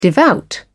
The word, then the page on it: devout: British English